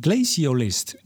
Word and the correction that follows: glaciologist: Nee, glaci glaciologist in het Engels, glacioloog in het Nederlands.